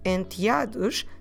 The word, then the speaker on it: enteados: Let me spell it for you in Portuguese so we can practice our alphabet and understand better how do we may pronounce this word. So, spelling it for you, em português, E-N-T-E-A-D-O-S.